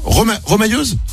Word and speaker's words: remailleuse: On dit, ça se prononce pas comme ça? Remailleuse? – Remailleuse, oui. – Remailleuse, pardon.